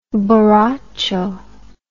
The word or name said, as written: boracho